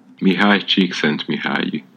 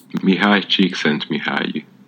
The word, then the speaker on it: Csikszentmihalyi: Deixa eu apertar aqui o do Wikipedia, o ouvir. Vamos lá. Mihai Csikszentmihalyi. Aham. Uhum. Mihai Csikszentmihalyi tá? Eu falei certo.